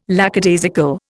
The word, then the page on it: lackadaisical: Indian